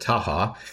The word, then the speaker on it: Tacha: After law school, you clerked for Judge Dinelle Tacha on the 10th Circuit. What was that experience like? – Yeah, forgive me for correcting the pronunciation, but she pronounces it Taha.